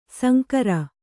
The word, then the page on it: ಶಂಕರ: śankara; Kannada